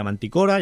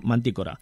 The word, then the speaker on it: Mantícora: Que, por cierto, hay gente que lo pronuncia Manticora y hay gente que lo pronuncia Mantícora. Aquí lo pone con acento en la I, por lo tanto, yo lo hago esdrújula, Mantícora.